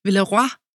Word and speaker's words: Villeroy: Und es ist ja wirklich was Besonderes, das Unternehmen auch, denn ich habe ja gelernt in der Vorbereitung, dass man Villeroy auch ganz anders aussprechen kann, nämlich Villeroy. Französisch.